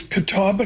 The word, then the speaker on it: Catawba: A zillion acres, Catawba County.